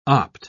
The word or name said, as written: opt